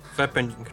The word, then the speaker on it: fappening: Y, tak zwany fappening, dobrze to wymawiam? Nie wiem. – Fappening, tak, fappening. Fappening.